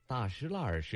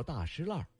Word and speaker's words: 大栅栏: 对于外地人来说，大石栏是个第一次读出来都会折在这里的坑。大栅栏，北京人读作大石栏，位于前门外，兴起于元代。